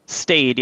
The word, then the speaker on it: staid: The stayed, which is the pronouns are Z, Zier, and how do you pronounce is that STAID or what? – Stayed, yeah, STAID.